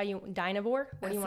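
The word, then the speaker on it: Dynevor: It's Denevore. – Denevore.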